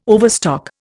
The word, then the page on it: overstock: Indian